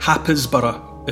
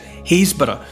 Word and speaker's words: Happisburgh: Haysborough, it looks more like Happisborough, if you look for it on the map. Happisburgh. But in that part of the world, they pronounce it Haysborough.